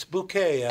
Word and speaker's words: Bucket: The British, she's an older woman, and everybody always thinks her name is something else, and she has the French pronunciation. I think it's Bouquet. I think she calls it. Is it Bucket? Yes, Mrs. Bucket. It's Bouquet, actually.